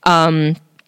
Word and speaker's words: Yunque: It's called Junque. El Junque. It's like Y-U-N-Q-U-E. El Junque. Yeah, it's pronounced with like a J, even though it's a Y-U-N-Q-U-E.